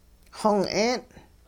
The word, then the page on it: xong-'een': hupa